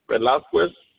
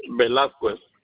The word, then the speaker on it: Velasquez: D-I-E. Just one D. – And your last name? – Velasquez. – Yes, what's your last name? – Velasquez. – Could you spell that, please? – V-E-L-A-S-Q-U-E-Z.